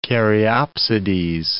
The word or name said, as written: caryopsides